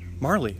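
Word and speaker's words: Majerle: Marley? Marley. Yeah, that's how you say it. Marley. It's spelled with a J. He has a silent J.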